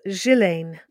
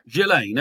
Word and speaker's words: Ghislaine: What about Ghislaine? And I'm told that's the proper pronunciation. Is it Ghislaine? Ghislaine? – No, it's Ghislaine. – Ghislaine. Okay. Okay, Ghislaine.